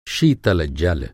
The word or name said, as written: शीतल